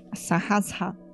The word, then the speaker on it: Sahasra: Sahasra. – É isso aí, que eu não consigo pronunciar nem... – Fala, vai, fala. – Essa. – Não, respira. Vamos te... Inspira. – São 3 sílabas.